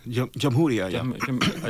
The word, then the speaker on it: Jamahiriya: Libya is as a socialist Jamahiriya. Is that how you say it? – Jamhuriya, I never even saw that word before.